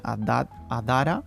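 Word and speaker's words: Adhara: Adara se escribe con H detrás de la D, Adara y eh, allí pues podemos encontrar, eh, dedicamos eh, parte de los servicios a la prevención, como a la parte educativa.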